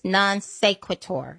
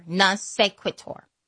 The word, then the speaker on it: non sequitur: One of them is, I always mess up how to pronounce it, but it's non sequitur. Non sequitur. I always have to say it twice. Non sequitur.